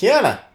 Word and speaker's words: Keanu: Keanu.